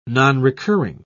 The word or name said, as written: nonrecurring